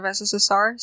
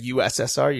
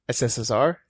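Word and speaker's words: СССР: So I noticed that you don't pronounce or say the U. You don't say like USSR. You just say SSSR. – Yeah. That's because in Russian you say SSSR.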